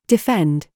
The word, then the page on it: FIFE: British English